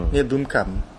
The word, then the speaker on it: Blomkamp: Neil Blomkamp.